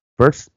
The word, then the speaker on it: Perth: en Perth. Perth, ¿verdad? Se pronuncia así,